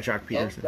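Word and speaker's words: Joc Pederson: And Bellinger and Seager and Pete Peterson, if I pronounce it. – Jock Peterson.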